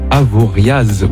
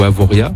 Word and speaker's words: Avoriaz: Au niveau mercure, il faut compter cet après-midi 17 à Avoriaz ou Avoria. – Avoria. – Avoria, merci. – On ne prononce pas le Z à la fin.